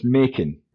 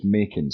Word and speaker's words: Makin: No, it's Makin. But it's, Makin. It's a very common mistake in Scotland. Mac is the go-to pronunciation, but it's Makin.